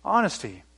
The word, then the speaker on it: honesty: Second H, honesty. Silent H, honesty.